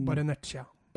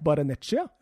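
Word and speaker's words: Barrenetxea: Men han heter Ander Barenetsexea. Baren... – Barenetsja. – Baren... Ba... Er det så lett? Barenetsja? – Ja.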